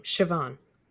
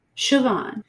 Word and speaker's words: Siobhan: And I know how to say the Irish woman's name, Siobhan. – Siobhan.